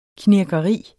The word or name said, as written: knirkeri